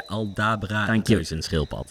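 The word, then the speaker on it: Aldabra reuzenschildpad: Zeg ik het goed zo? – Ja, Aldebra reuzenschildpad.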